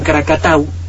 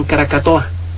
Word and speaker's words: Krakatau: Poco a poco se convirtió en Krakatau, y ya le he platicado que los colonos holandeses en aquella época mal la pronunciaban Krakatoa.